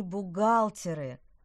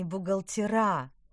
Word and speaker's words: бухгалтеры: Попробуйте группе бухгалтеров сказать, что они бухгалтеры, а не бухгалтера.